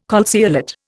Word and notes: calceolate: Indian

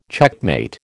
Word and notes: checkmate: American English

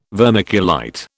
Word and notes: vermiculite: British English

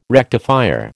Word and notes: rectifier: American English

